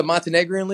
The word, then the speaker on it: Montenegrin: It's the Montenegrin League.